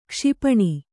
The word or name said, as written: ಕ್ಷಿಪಣಿ